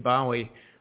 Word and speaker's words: Bowie: You know, and during their time together, you know, people like Little George of Little Feet, and I mentioned David Bowie, was, and that's really the correct way to pronounce his name if you're in England.